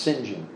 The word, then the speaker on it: St. John: It looks like Harold St. John, but it's pronounced St. John.